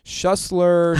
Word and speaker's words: Schuessler: So you just kind of have to, like, I literally, for my college graduation, I had to write down the word shoe and the word slur. Literally, yeah. Like, and that's just the only way they got it right. That's the only way they pronounce it right, because people look at my name and they're like, Schuller, Schuessler, Schuessler.